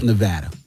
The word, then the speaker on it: Nevada: How does it help Nevada? – Yeah.